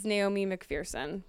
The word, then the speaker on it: Naomi McPherson: Okay, well, so our guest is Naomi McPherson.